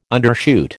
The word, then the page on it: undershoot: American English